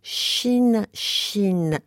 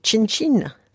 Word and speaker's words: Shin Shin: Avec Odile Conseil, Lucas Fournier, Jeanne Carillon et Véronique Brindeau, on s'intéresse aujourd'hui à Shin Shin. Se prononce comme le chi de Chine. C'est bien ça, Véronique, et non pas comme Chin Chin.